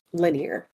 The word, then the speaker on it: Lanier: Lanier, I'm sorry, it's spelled L-A-N, but it's pronounced Lanier.